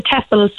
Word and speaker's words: TEFL: It's pronounced TEFL, I think. Am I correct? – Yes, that's correct. The TEFL.